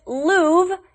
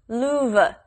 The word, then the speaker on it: Louvre: Most Americans just say it Louvre, even though there's an R in there. We don't really pronounce it, although I believe you can also say it Louvre and kind of make it two syllables.